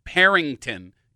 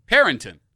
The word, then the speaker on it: Perinton: It's spelled, unless you're referring to a different place, P-E-R-I-N-T-O-N. – So there's no G.